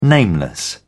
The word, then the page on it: nameless: British English